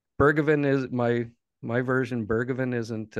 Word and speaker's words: Bergevin: And you make a good point Bergevin? I can't pronounce it nearly as well as you. Is that close? Bergevin? – Pretty close.